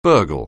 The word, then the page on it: burgle: anglais